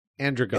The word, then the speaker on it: Androgum: So he identifies as an androgum. – Androgum. – It looks like Androgum. And that's how they pronounce it in a less goofy way.